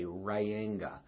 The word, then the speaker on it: Reyenga: You know, it's sort of like my last name is spelled R-E-Y-E-N-G-A, Ryanga.